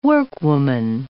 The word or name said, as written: workwoman